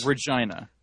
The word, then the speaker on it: Regina: Regina is the capital of Saskatoon and the city that rhymes with fun.